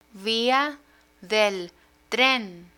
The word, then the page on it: via del tren: Spanish